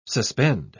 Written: suspend

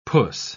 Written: puss

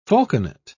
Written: falconet

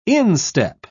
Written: instep

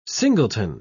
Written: singleton

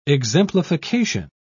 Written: exemplification

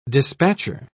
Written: dispatcher

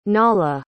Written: nahla

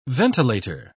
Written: ventilator

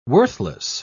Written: worthless

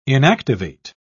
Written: inactivate

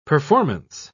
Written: performance

